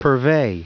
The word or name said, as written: purvey